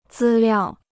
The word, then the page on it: 资料: zī liào